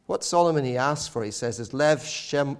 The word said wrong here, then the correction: lev shomea: Let's get the pronunciation right for Hebrew scholars among us. Is lev shomea, lev shomea.